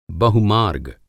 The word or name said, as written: बहुमार्ग